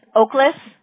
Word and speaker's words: Oklejas: Now, for the account purpose, I have your first name is Victor, V-I-C-T-O-R. What's your last name? – It's Oakless, and it's spelled O-K-L-E-J-A-S. – Okay. Oakless? Is that how you pronounce it? Okay. – Yeah. – And J is silent.